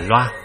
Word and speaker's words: La: Đó là Cung Tỳ La đại tướng, chữ La có khi đọc là Loa,